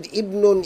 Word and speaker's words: ibn: Number two, again the word ibnun is preceded by a word. So we are not going to pronounce the hamza. We say kharaja ibn at-tabib min al-bayt.